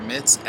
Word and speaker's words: Mitz: My name is Laguane Warrior Mitz, LOGWONE.